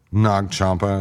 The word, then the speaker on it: Nag Champa: You know, it smelled like Nag Champa. Is that how you pronounce that? – Yeah, the incense.